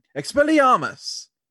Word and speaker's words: Expelliarmus: To say it completely right, you have to say it with slight accent. Expelliarmus.